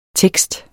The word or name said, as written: tekster